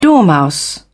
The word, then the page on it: dormouse: British English